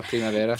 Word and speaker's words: Primavera: I've not got the pronunciation like yourself, in Spanish. – No, Primavera.